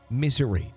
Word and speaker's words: Missouri: And it's pronounced more like misery instead of Missouri.